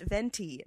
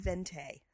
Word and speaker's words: venti: it's pronounced venti, not vente.